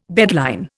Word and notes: bedline: Indian

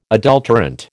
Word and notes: adulterant: American English

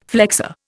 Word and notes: flexor: Indian

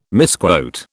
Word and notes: misquote: British English

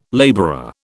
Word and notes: labourer: British English

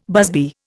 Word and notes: busby: Indian

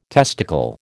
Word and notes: testicle: American English

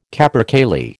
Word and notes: capercaillie: American English

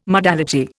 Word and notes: modality: Indian

